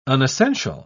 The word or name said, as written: unessential